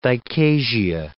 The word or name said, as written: dichasia